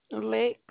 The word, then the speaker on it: Lex: Lex. – Can you spell that? – L-E-X. – L-E-X? – Yes.